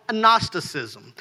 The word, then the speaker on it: Gnosticism: It sounds like it starts with an M. I don't understand it. But it's called Gnosticism.